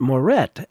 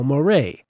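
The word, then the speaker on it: Moret: Neil Murray's is one of the names I've apparently been butchering on the show for lo these many years, pronouncing it Morette as it's spelled. But at this year's West Coast Ragtime Festival, I don't remember who, but someone played one of his tunes and introduced it as by Neil Murray.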